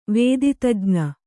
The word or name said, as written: ವೇದಿತಜ್ಞ